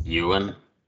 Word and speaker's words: Ewan: Ewan, is that how you pronounce it? – Ewan.